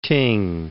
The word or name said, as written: ting